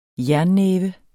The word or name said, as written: jernnæve